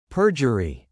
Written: perjury

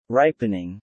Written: Ripening

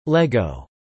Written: LEGO

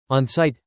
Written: on-site

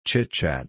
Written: chitchat

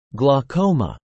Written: Glaucoma

